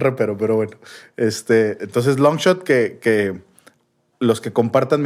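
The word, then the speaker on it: LNGSHOT: Sí, no, es, es Longshot, LNG, diagonal, H T, Longshot.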